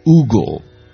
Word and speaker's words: Hugo: There's a beautiful poetic rendition by the French poet Victor, I have to now say it properly, Hugo.